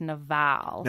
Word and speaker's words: Nabal: His name, the Hebrew pronunciation of his name is Nabal.